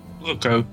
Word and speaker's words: Kuroko: What, how's it pronounced? Karuko? – Kuroko. I think it's called... Yeah, Kuroko, sorry.